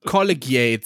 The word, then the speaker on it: Collegiate: Collegiate, sage ich. Collegiate spricht man das aus.